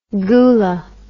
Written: goola

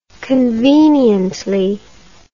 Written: conveniently

